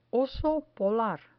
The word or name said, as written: oso polar